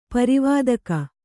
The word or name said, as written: ಪರಿವಾದಕ